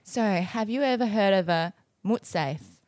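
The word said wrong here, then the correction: mortsafe: A mutsafe? Is that how you pronounce it? What a mort. Can you just spell it? – A mort safe.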